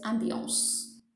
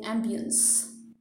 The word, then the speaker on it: ambiance: That is why it's ambiance. But you will hear some people saying ambience. Those are mainly those who cannot conform to the French pronunciation, and so they just keep it simple and they say ambience.